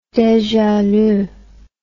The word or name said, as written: deja lu